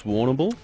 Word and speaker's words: Warrnambool: I believe it's Warrnambool. – Warrnambool. – Yeah. Yep. – Oh, I'm saying it right.